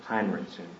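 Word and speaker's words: Henrichsen: It's Walt Hendrickson? – Heinrichson is how you say that. Like I was saying, it's Walt Heinrichson.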